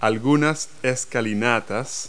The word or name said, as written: steps